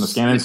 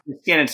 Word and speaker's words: Niskanen: Is this Niskanen Center. – Niskanen Center, yes.